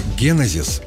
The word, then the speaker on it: генезис: Слово «генезис» – одно из самых сложных в русском языке. Дело в том, что ударение в нем предпочтительно ставить на первый слог.